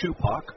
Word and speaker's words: Supak: Last name is S-U-P-A-K. It's pronounced Schupach.